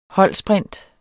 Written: holdsprint

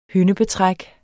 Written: hyndebetræk

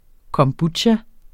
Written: kombucha

